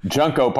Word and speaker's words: Junko: Junko partner is pronounced that way because it's about the junk.